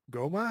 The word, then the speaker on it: Gohma: Anyway, so you're essentially there to fight off the Goma, I think is how you pronounce it. It's like G-O-H-M-A, along with the other 7 generals.